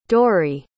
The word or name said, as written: Dori